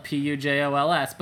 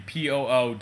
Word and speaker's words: Pujols: Unfortunate because he's not American, and Pujols is probably a very normal name, especially the way it's spelled, Pujols, but Americans pronounce it Pujols. – And if you go on ESPN, actually, fun fact, go to his player page. When it looks under pronunciation, it is actually Poo-Holes, meaning his name literally means the hole that your poop comes out of.